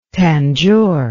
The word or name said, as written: Tanjore